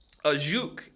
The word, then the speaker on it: Ajuch: I think it's called the Ajuk. – Ajuk. A-J-U-C-H. Ajuk.